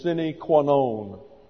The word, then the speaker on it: sine qua non: That's spelled S-I-N-E, second word Q-U-A, third word N-O-N. According to Latin pronunciation, it is sine qua non.